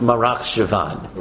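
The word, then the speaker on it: Marcheshvan: Mar Cheshvan. – Right, so there's a funny the month of Cheshvan, which really ought to be pronounced Marach Shavan.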